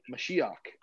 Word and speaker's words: Mashiach: And what is the word Messiah, or how do you say anointed one in Hebrew? Mashiach.